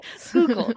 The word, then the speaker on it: Schuylkill: Schuylkill.